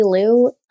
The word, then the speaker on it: Lew: Yeah, so it's just my name, Natalie Liu, and Liu is spelled L-E-W, .com.